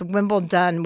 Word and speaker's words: Wimbledon: It's actually not with a T. It's Wimbledon with a D. – Wimbledon, I can't even say it right. – No, that's the right way.